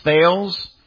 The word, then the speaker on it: Thales: It's a company by the name of Thales, T-H-A-L-E-S. You would think that would be pronounced Thales, but it's actually pronounced Thales.